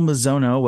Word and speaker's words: Mizono: Anna Seregina and Kyle Mizono, welcome to the broadcast.